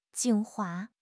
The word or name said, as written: 精华